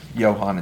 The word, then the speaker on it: Johan: It's Wohan. – Wohan? I don't believe so. – You sure? – Yeah. Johan, it's the soft J.